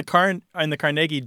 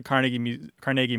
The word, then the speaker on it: Carnegie: So it's only in New York that it's pronounced Carnegie. – Well, mostly, and people who learned it from New Yorkers. – Carnegie. – Yeah. – Yeah, Western Pennsylvania, they get Carnegie right.